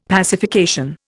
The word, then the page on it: pacification: Indian